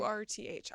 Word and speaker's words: Urth: I went to Earth. How do you say that? Earth Cafe. – Oh yes. – Have you been there? – URTH.